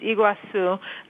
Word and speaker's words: Iguazu: Yeah, well, your pronunciation was very, very close. It's Iguazu, both in actually in Portuguese, with in Spanish they use a Z instead of the C with the little squiggly.